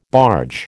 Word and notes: barge: American English

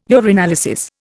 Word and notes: urinalysis: Indian

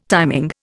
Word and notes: timing: Indian

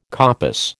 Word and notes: coppice: American English

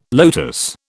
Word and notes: lotus: British English

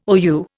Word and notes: OU: Indian

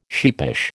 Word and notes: sheepish: American English

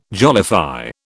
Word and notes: jollify: British English

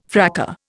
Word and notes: fracas: Indian

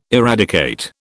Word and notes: eradicate: British English

